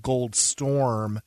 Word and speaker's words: Goldsturm: And this one, the most popular and wildly popular cultivar is called Gold Storm, or is spelled Goldsturm, S-T-U-R-M.